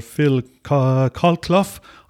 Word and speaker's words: Phil Colclough: an Englishman called Phil Colclough, I think is the correct pronunciation of his surname.